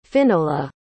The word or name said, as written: Finola